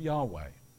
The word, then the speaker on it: Yahweh: This is the translation of the original name in the Hebrew, which is YHWH, we pronounce Yahweh.